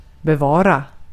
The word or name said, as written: tutmak